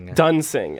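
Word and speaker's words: Duensing: I want to pronounce it Dwensing every time. – Dunsing. – And one of these days I'll get it right.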